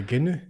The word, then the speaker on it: genoux: Les genoux. – Yeah. – Genoux. – I think, don't they pronounce G's? – No, I mean like Gilles.